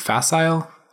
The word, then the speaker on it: facile: this facile, how do you say that word? Facile? – Facile.